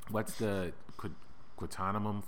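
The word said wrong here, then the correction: Tahquamenon: Falls? I'm not saying it right. – Taquamenon. – Thank you, yeah.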